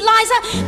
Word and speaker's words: Liza: It's Liza with a Z, not Lisa with an S, because Lisa with an S goes snubs. It's Z instead of S, lie instead of Lee.